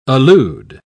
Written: allude

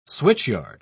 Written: switchyard

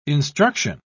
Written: instruction

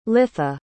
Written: litha

